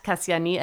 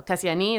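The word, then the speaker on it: Kassiani: My Instagram is at Kasiani Eleni, which is my name in Greek.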